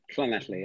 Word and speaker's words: Llanelli: You grew up in Llanelli. Is that how you say it? – Llanelli, yeah.